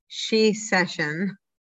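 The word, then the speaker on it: Shecession: And then on March 8th for International Women's Day, Unpacking the She Session. Am I saying that right? – Yeah, like it's a recession, but for just women.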